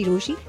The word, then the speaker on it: Rochie: मेरा नाम है मिर्ची रोशी, नाम की स्पेलिंग है ROCHIE, and today is my rant day.